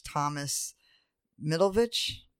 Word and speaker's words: Middleditch: Thomas Middleditch.